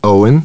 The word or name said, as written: Owen